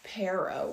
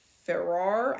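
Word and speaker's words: perro: And I thought the word "dog" was F-E-R-R-O-R, Pharaoh. And I guess it's Pero with a P. So I kind of lost my confidence to say Ferrar, because I knew how to spell it, I didn't know how to say it.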